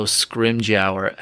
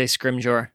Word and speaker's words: Scrimgeour: There's no Scrimgeour. How do you pronounce his name? – I always say Scrimgeour.